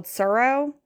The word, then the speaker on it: Tsuro: We also like, it's called Suro. It's spelled with a T in the beginning, T-S-U-R-O.